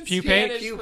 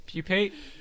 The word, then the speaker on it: pupate: Like pupate? – Pupate? – Pupate.